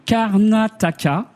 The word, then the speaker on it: Karnataka: La région, c'est le Karnataka.